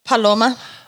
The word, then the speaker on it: Paloma: All right, so how do you say that? The first word. – Paloma.